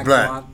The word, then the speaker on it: Blatt: Am I pronouncing correctly? – Yes, Blatt.